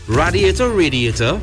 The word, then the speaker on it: radiator: especially if you have a radiator leak. Radiator? Is it? – Radiator. – But it's spelled R-A, not R-A-Y.